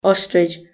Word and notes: ostrich: British English

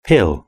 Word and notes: pill: British English

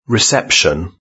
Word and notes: reception: British English

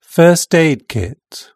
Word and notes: first aid kit: British English

